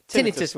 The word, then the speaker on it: Tinnitus: And the reason for that is because it is Tinnitus Week. I've nearly said it wrong again. Tinnitus Week. Which is about the sort of medical condition where you can sort of hear things ringing in your ears and so on.